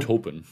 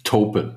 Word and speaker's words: Taupin: Und über so einen Helfer hast du hier auch schon gesprochen, den Turpin richtig ausgesprochen? – Bernie, ja? Taupin. – Taupin, ah, siehst du.